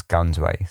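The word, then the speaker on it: Ganj-i-Sawai: And, you know, the reports of what happened aboard the okay, I really struggle with this pronunciation, the Ganji Sui. So apparently this is anglicized as Gunsway. So for this purpose of this chat now, I'm just going to call it the Gunsway.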